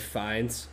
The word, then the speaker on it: Fiennes: I like Ralph Fiennes a lot. I think he's great. I also love any excuse to say Ralph Fiennes, because it's a nice little tidbit to throw in a party.